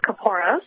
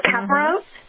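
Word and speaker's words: Kaporos: Well, there are several pronunciations. We tend to stick to the pronunciation of Caporos, but you might hear others refer to it as Caporos or Caporos.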